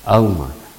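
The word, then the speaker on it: алма (עלמה): В еврейском тексте «ха алма», вот последнее «ха» не произносится, «алма». «Ха» – это определенный артикль.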